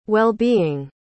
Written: wellbeing

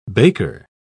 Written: baker

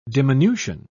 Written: diminution

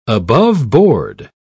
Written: aboveboard

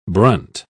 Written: brunt

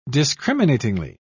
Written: discriminatingly

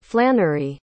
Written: flannery

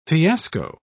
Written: fiasco

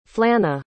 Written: flanna

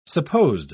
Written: supposed

